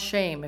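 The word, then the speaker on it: shame (שם): The word name in the Hebrew is either Bishem or Shem, but it's pronounced shame if you pronounce it correctly. Doesn't that sound odd to you? The name is shame.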